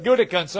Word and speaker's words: Gutekunst: Ted Thompson was a lot easier than Brian Gutekunst. I think that's how you say it. – I think that's it. Look, that's as good for me as it is for you. I'll go with Gutekunst.